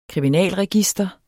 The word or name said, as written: kriminalregister